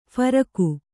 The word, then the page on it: ಫರಕ: pharaka